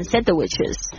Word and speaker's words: sandwiches: Two tuna sandwiches 把它学起来，这个 sand 先千万不要把那个 D 念的念出来、哦、 sandwiches 不要念成 sandwiches 哦，不要这样子呢，你可能永远都吃不到三明治，好可怜哦。好 sandwiches 那个 D 呢不要念出来， sandwiches 因为两份所以后面加上 ES 要发成 cheese sandwiches。